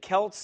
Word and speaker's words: Celts: About 387 BC or shortly before, some 300,000 Sinons, one of the Celts, is it Celts, C-E-L-T-S? Let me make sure I say that right.